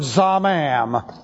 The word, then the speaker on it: zamam: With this, we have the calactive participle from the verb zamam. Z-A-M-A-M. To pronounce it, you have to press your lips together, and it means conspiring secretly.